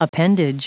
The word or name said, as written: appendage